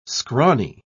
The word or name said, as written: scrawny